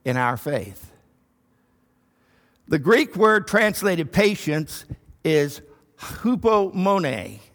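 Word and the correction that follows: hupomone (ὑπομονή): I didn't really sound it out well there, but hupomone is how it's said.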